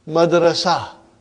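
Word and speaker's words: Madrasa (مدرسة): Madrasa. Are you with me? What will you say? Madrasa. You did not pronounce ta marbuta, but you pronounce it as ha.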